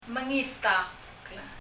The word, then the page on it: mengitákl: [məŋitʌkl]